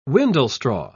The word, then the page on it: windlestraw: British English